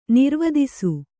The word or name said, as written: ನಿರ್ವದಿಸು